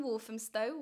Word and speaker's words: Walthamstow: And thank you for taking the time to come to my little house in Walthamstow.